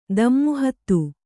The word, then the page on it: ದಮ್ಮುಹತ್ತು: dammu hattu